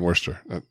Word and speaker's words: Worcester: Good pronunciation there on Worcester.